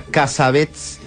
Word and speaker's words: Cassavetes: ¿Cómo se pronuncia Cassavetes en Estados Unidos? – Cassavetes.